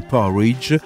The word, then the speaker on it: Porij: Questo tocco di elettronica mi fa pensare al brano prossimo che sentiamo di una band giovanissima, i Porridge, scritto P-O-R-I-J, si dovrebbe pronunciare così, che ricorda un tipico piatto inglese.